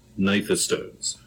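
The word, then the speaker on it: gnathostomes: Yeah, certainly you want to go back through a lot of ostracoderms because those are all, technical term coming up, stem group nathostomes. So nathostomes are the jawed vertebrates. – Spelled with a G. – Yeah, spelled with a G, like Nash. – Yeah. – Okay. – Yeah. – Nasher. – G-N-A-T.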